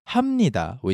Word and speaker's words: 합니다: is not pronounced as H-A-P-N-I-D-A, 합니다. And why we pronounce it as 합니다, which is H-A-M-N-I-D-A.